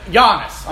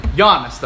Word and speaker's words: Giannis: Guinness. – Is that his name? – Giannis. I'm sorry. Not Guinness. – Monte DeCumbo. – Giannis. – Is that how you say it? – Yeah, something like that. Giannis, though.